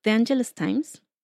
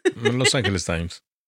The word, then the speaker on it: Los Angeles Times: En el 2004, la periodista freelance Leslie Kornstein publicó un artículo sobre esta historia en Los Angeles Times, de, ¿cómo se pronuncia? The Angeles Times, como sea. – Los Angeles Times.